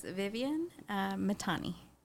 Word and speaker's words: Viviane Mithani: Yes, it's Viviane Mithani.